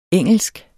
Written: engelsk

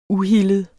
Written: uhildet

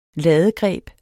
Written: ladegreb

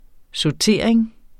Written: sautering

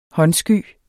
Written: håndsky

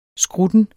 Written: skrutten

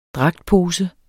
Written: dragtpose

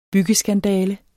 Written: byggeskandale

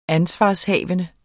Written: ansvarshavende

